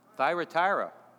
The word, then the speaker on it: Thyatira: Thyatira.